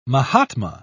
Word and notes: mahatma: British English

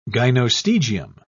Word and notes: gynostegium: British English

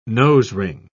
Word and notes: nosering: British English

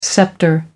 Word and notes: sceptre: American English

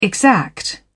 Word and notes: exact: British English

